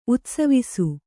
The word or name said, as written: ಉತ್ಸವಿಸು